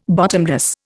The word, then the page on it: bottomless: Indian